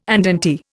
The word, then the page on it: andante: Indian